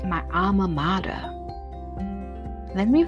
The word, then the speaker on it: mater: Mother is the modern English equivalent of an old English modor, M-O-D-O-R, pronounced mater. This comes from the Latin word mater, M-A-T-E-R, pronounced mater, mater. Okay, pronounced mater, M-A-H-T-E-R, that's how you pronounce it.